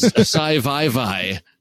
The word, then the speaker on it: Civivi: Civivi? – Civivi, I like... – Did he pronounce that? – Oh, he did. Did I pronounce that? It's Sci-Vi-Vi. My gosh. He pronounced... That's C-1-5-1-1. – That's acceptable. Exactly. C-1-5-1-5-1-9.